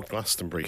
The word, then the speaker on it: Glastonbury: Hello, Glastonbury, how are we doing? – What's up, Glastonbury? – So the British ones say brie, like the cheese.